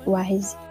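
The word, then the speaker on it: Weyes: É, só que Wise se escreve diferente, mas pronuncia Wise, que nem sabe.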